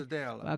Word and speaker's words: Brasadella: And John, in your book, you have this, is it Brasadella recipe? Did I say that right? Recipe? – Brasadella.